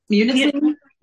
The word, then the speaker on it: Munising: Fort Superior was the day, it was before, it was in, I can't pronounce this. Is it Munising? – Munising? Yeah.